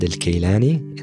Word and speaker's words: Kilani: My name is Muhammad Kilani. It's pronounced Muhammad al-Kilani in Arabic.